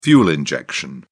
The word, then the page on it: fuel injection: British English